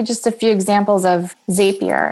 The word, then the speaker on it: Zapier: Zapier, Z-A-P-I-E-R.